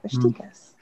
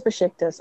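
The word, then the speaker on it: Beşiktaş: Becky Stas. How do you pronounce it? – Besiktas. I know it's Besiktas, isn't it? – It is Besiktas.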